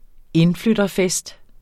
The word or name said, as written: indflytterfest